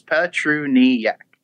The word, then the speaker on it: Petruniak: How close did I come to nailing the last name? – Not even close. – No? Petronuk? – No. – No? Nope. Okay. – Four syllables. Petruniak. – Petruniak.